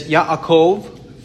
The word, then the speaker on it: Yaakov: In the Old Testament, the name in the Hebrew language is transliterated Jacob, and we pronounce it Jacob. It is Yaakov from Hebrew.